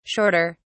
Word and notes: shorter: British English

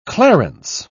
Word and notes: clarence: British English

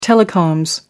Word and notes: telecoms: American English

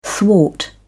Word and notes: thwart: British English

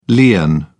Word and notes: lien: British English

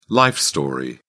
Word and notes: life story: British English

